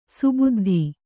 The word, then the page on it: ಸುಬುದ್ಧಿ: kannada